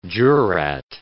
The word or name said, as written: jurat